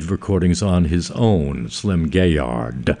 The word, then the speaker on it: Gaillard: Dinah Washington, 1949, and Slim Gaillard. I'm told it also could be pronounced Gaillard. And if it was pronounced the French way, they'd pronounce it a whole different way, but he was American, so.